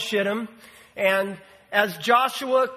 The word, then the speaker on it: Shittim: You know, I did a study, and I wanted to know how the Hebrew word was supposed to be pronounced. And I really did quite a study on this. And you know what I discovered? It's Shittim.